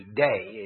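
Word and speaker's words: day (δεῖ): There's a Greek word, it's not a very long one, it's D-I-E, day is the way it's pronounced.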